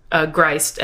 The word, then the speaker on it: Greist: I got an email from one of them asking that because my last name is Greist and it rhymes with Christ, that if hypothetically I ever had a child, if it would be the Greist child.